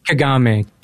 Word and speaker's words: Kagame: Kagame. K-A-G-A-M-E.